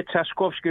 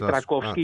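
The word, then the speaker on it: Trzaskowski: Δηλαδή, ο Ραφάλ Τρατσικόφσκι, σωστά το προφέρω, πώς είναι, είναι Τσασκόφσκι, όχι Τρακόφσκι, γιατί όταν είναι ρο ζήτα γίνεται επικρατεί το σύμπτωμα.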